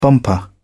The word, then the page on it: bumper: British English